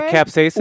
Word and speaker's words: capsaicin: Is it capsaicin? – Well, okay, so I also bought. This is war. – Right? – Yeah, it is capsaicin. Yeah. I can't pronounce it. – Capsaicin. – Yes, what you said.